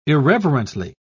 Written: irreverently

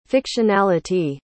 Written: fictionality

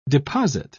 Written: deposit

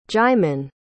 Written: djimon

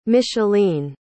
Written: micheline

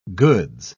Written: goods

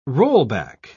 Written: rollback

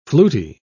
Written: fluty

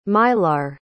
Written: Mylar

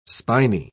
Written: spiny

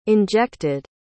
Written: injected

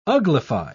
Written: uglify

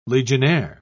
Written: legionnaire

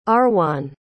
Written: Arwan

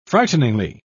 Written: frighteningly

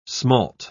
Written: smalt

